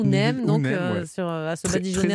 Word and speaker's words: Neem: Alors, pour les faire fuir, il y a une huile qui est très utilisée en Afrique qui s'appelle l'huile de Neem ou l'huile de Nîmes. Je sais jamais comment on la prononce. C'est Neem.